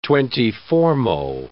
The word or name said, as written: twenty-fourmo